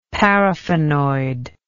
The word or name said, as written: paraffinoid